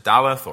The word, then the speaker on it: Dalet: This fourth section, the fourth letter of the Hebrew alphabet, we can either pronounce it Daleth or Dalet, and it signifies in its pictorial sense one of two things.